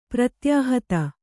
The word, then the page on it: ಪ್ರತ್ಯಾಹತ: pratyāhata